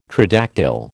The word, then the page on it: tridactyl: American English